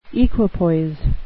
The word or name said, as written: equipoise